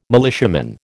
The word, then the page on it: militiaman: American English